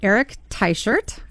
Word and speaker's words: Eric Teichert: So, without further ado, I'd like to introduce our guest, which I'm very excited about, as I mentioned, Eric Teichert.